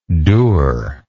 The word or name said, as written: dure